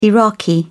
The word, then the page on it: iraqi: British English